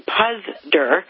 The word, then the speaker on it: Puzder: There's also another Ohio story, which is that the nominee for Labor Secretary, Andrew Puzder, is how he pronounces his name, I believe, is also from Cleveland, Ohio.